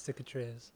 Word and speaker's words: Cicatriz: But this week, I really gravitated towards Cicatriz, right? That's how you say it, Cicatriz? – Yes, Cicatriz.